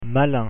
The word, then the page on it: malin: French